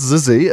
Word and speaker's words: Xyzzy: it's pronounced Zizi, as I learned when someone said it last night. – Right. – So I'm not an expert. Spelt XYZZY.